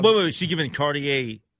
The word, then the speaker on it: Cartier: Some of the items I'm looking at here on page six, let's see, we have a Cartier, is that how you pronounce it? – Cartier, yeah.